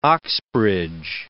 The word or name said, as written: Oxbridge